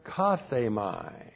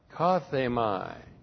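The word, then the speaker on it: kathemai (κάθημαι): The verb here, kathemai. K A T H long E M A I kathemai.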